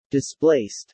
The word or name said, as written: displaced